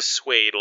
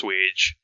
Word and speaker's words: swage: I thought it was a swade, like persuade, but I found out, no, the actual word is a swage.